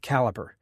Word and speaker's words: Calibre: Caliber, that's C-A-L-I-B-R-E, is often mispronounced as Calibre, because it's spelled in the UK styling of the word. That could be because the primary developer, Covid Goyal, is Indian and grew up speaking the Queen's English. But I assure you, the proper pronunciation is Caliber, just as in the diameter of a cylinder.